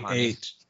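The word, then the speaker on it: Jhonny: That would be more J-H. Johanny.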